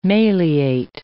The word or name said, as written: maleate